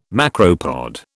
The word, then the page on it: macropod: British English